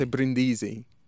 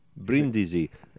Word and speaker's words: Brindisi: The emphasis is very important in Italian. – Brindisi. – You can't say Brindisi. – Brindisi.